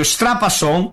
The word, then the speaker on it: Strapasson: Dese cognomi, per esempio, Strapasson, che se pronunciai Strapasson come se fosse pizza, in Italia, sia Veneto, sia noi che sia, i ga il tz, come gli metti un te.